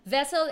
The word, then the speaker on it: Vesl: Vessel is spelled V-E-S-L, right? – It's right. Vessel Thank you. – Trade yeah. Finance. Yeah. It's so wrong again, spelling. It's V-E-S-L.